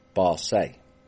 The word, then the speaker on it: base: But what was being smoked south of the border was a paste-like substance called Barce, which, when read, is base, but it's pronounced Barce.